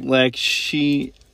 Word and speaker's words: Lakshmi: So just the abundance card is, do you want to pronounce that name? It's a Hindu goddess. Lakshmi? – Lakshmi. Yeah. – Lakshmi. – Yes.